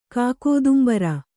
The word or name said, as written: ಕಾಕೋದುಂಬರ